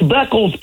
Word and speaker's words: Beckles: We're just mispronouncing names. It's Beckles, Playboy. BECKLES.